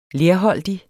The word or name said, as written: lerholdig